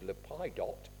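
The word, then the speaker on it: Lappidoth: She has a husband called Lepidot. That's how you pronounce his name.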